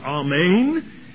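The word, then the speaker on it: Amen (אָמֵן): The word in the Old Testament, the Hebrew word, is to be pronounced Amen,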